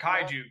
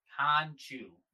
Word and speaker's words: Khonshu: It's not Kaiju, because that's what I keep wanting to say. – It's not Khonshu? – Khonshu. – Sure, Khonshu.